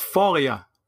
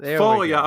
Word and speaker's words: Foglia: It's Foglia. – Foglia. – Foglia, a fine Italian name that the teachers butchered for 12 years.